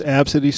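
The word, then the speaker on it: Abcde: You want to do this Ab City story? – Yeah, let's do the Ab City story. – Yeah. – Abcadide. – You pronounce it Ab City.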